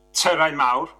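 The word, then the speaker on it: Tyrau Mawr: So, I'm just going to correct your pronunciation. So it's a Terrain Mawr, which means big towers, but it's also the name of one of the peaks around Cadair Idris, which is the mountain where this 20-minute looping video is set.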